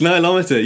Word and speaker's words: Nilometer: And I wonder if we can uh, start with another artifact that you visited, the Nilometer. Am I pronouncing that correctly? – Yes, Nilometer.